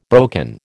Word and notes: broken: American English

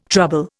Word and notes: trouble: Indian

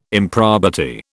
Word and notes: improbity: British English

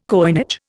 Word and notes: coinage: Indian

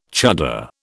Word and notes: chuddar: British English

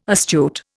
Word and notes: astute: Indian

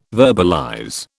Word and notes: verbalize: British English

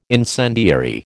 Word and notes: incendiary: American English

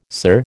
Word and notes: sir: American English

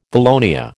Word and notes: valonia: American English

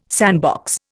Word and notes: sandbox: Indian

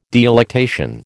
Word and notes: delectation: American English

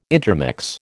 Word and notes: intermix: American English

